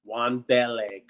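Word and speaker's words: Wan'Dale: I think Matt Corral or Wandele. Did I say that right? – Wandele, correct.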